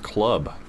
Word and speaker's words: CLWB: I think it's pronounced club.